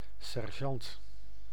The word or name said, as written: sergeant